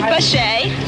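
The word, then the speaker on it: Baché: Dee Dee Bachet. – Spell it. – B-A-C-H-E with a slash on the E.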